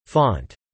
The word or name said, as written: font